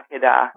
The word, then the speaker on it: Akedah: Akedah? – Yeah, it depends on how you're... Yeah, Akedah.